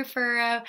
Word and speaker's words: Furrow: I did this Q&A on my Instagram, so if you guys aren't familiar with that, it's just Carter Furrow. My last name is spelled F-U-R-R-O-W.